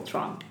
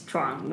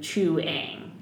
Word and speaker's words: Chuang: It's Chuang, one syllable. There are a lot of people who do Chuang, and that is incorrect.